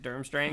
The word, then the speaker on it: Durmstrang: Is that how you say it? Durmstrang? – Yeah.